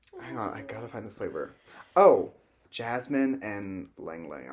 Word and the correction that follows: Ylang Ylang: I'm going to butcher that, but it smells so good. – Oh, Ylang Ylang.